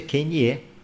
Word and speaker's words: Kanye: Kane ficou pistola. Kane que fala, né o Kane, como é que fala? – Kane? Acho que é Kanye.